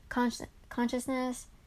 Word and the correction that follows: conscientiousness: I don't know how to pronounce it, honestly. Conscientiousness, there we go.